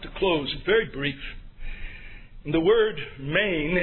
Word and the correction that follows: mien: M-I-E-N, it's pronounced mean. I'm sorry, not main, but mean.